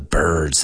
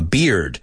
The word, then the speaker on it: beard: Just be careful with the pronunciation, because you don't know how many students of mine tell me that I have a bird on my face. Y no, I have a beard. Right? I don't have a bird. I have a beard. So be careful. You don't mispronounce those words.